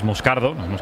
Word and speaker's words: Moscardo: Este chico, como bien ha pronunciado Unai, es Moscardo, es brasileño, sí.